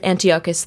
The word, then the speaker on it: Antiochus: You know, he's a Greek living in Syria, not so nice, kind of a dictator, Antiochus IV. And emphasis on the first syllable more than on the second syllable.